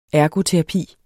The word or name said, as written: ergoterapi